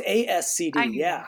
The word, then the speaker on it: ASCD: Did I say that right? I always get them mixed up. – I think you did. – Or is it ASCD? – No, it's ASCD. Yeah. – I always say it opposite.